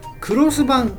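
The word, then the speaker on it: X版: うん、 X 版と書いてあったんで、えー、 X 版って読むなと思ったら、クロス版だったんですね。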